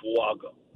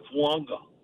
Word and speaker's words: Fuaga: He said it's okay to call him Fuaga. – Okay. – That's not what he told us. It's really, it's almost like Fuanga. – Fuanga, the N.